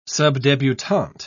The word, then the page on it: subdebutante: British English